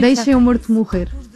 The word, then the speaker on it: Deixa-me Morto Morrer: There is a track called Deixa-me Morto Morrer. Mm-hmm. Am I pronouncing that? Am I butchering that pronunciation? – It's in Portuguese, it's very hard to pronounce Portuguese, but it's almost Portuguese.